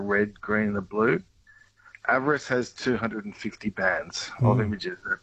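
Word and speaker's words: AVIRIS: Now, I hope I'm saying it right. What is the NASA JPL a virus mission? – Yeah, we say avarice. – Avarice. – But that's okay. Yeah.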